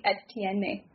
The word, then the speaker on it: Etienne: I always get, I feel like I want to like over French his name. no. Travis Etienne. Like Etienne. Am I saying it right? – Yeah. No, you're saying it right.